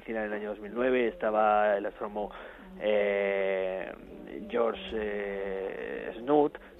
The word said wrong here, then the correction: Smoot: Smoot, que siempre lo digo mal, estaba la directora del Instituto SETI, con la que tuvimos el placer de hablar, que es Jill Tarter.